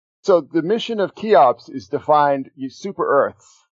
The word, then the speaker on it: CHEOPS: CHEOPS, yeah. – CHEOPS. – Yeah, the key, that CH is Greek. It's a K sound.